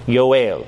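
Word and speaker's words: Yoel (יואל): The Hebrew term for Joel is Yoel. Yoel.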